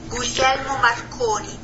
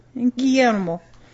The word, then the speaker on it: Guglielmo: Guglielmo Marconi. Yeah, I was the one who was saying it right. – Guglielmo Marconi. – Guglielmo.